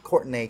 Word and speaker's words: Courtenay: I'm your host, Patrick Thomas Perkins, PTP, and I am joined by one of my dear friends, Courtenay Collins. Am I saying it right? – Courtenay. But yes.